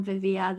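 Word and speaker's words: Viviers: So if you can handle the silent S on Vivia, then you can find me.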